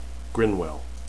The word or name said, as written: grinwell